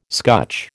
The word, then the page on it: scutch: American English